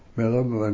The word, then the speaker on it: Millom: Which was Milham, and that was... – Spell that for me. – M-I-L-L-O-M.